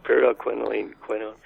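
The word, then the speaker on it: pyrroloquinoline quinone: Let's see, pyroloquinolone quinone. How am I doing on that? – Right. Yeah, you did pretty well. Pyroloquinolone quinone.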